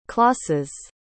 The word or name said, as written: clause's